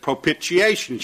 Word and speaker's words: propitiation: She said, how do you pronounce P-R-O-P-I-T-I-A-T-I-O-N? I said, propitiation. She said, wrong. She said, that T is silent again. I said, well, what is it? She says, propitiation.